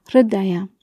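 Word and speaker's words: Hridaya (हृदय): Vielleicht habt ihr es auch schon als Hridaya oder so gehört, aber die Aussprache ist, es gibt ein kurzes A und das H und das R spricht man zusammen R aus und dann Daya, also Hridaya.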